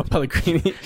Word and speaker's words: Pellegrini: The soon-to-be late Manuel Pellegrini. – He stopped dying of cancer. – Right? That's how you say it? – Yeah.